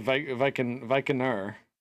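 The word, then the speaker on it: víkingr: And it was actually Vikingur is the actual, like, pronunciation. There's, like, an R at the end for the original word. But no vowel before the R.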